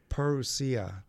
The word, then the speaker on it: parousia: parousia. It's a Greek word. – How do you spell it? – P-A-R-O-U-S-I-A, parousia.